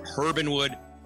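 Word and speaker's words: Herb & Wood: but we're supposed to pronounce the H, Herbinwood,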